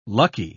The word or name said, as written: lucky